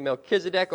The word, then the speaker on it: Melchizedek: Okay, Melchizedek, or if we're really technical, you could say Melchizedek. Okay, put the emphasis there on the second to last syllable.